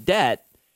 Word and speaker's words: debt: D-E-B-T. Debt. I don't know. That I feel like the B is silent.